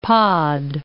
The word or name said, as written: pod